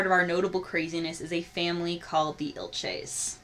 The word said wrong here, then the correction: Ilich: I think it's Ilich. – Ilich, Ilches, Ilich. You're right.